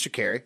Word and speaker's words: Sha'Carri: I think it's Sha'Carri. I looked up the pronunciation.